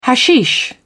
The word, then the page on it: hashish: British English